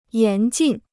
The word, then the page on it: 严禁: yán jìn